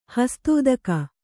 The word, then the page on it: ಹಸ್ತೋದಕ: hastōdaka